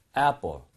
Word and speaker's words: apple: A 발음을 할 때 원래 A라고 하는 스펠링이 나오면요. 대부분 입모양을 많이 벌리셔야 돼요. 그래서 에 이렇게 하는 게 아니고요. 에 이렇게 발음하게 됩니다. So, apple, apple. 많이 벌리세요.